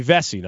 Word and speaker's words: Vesey: It'll be August 15th this summer for Jimmy Vesey. Now, correct me if I'm saying it wrong. I've been told it's Vesey, and now I'm hearing VC all the time. – Yeah, I've heard VC.